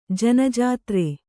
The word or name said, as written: ಜ್ಞಾತೃ